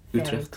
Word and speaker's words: Utrecht: which used to be a prison here in Utrecht. Am I pronouncing it correctly? – Utrecht? Very correctly. Yes.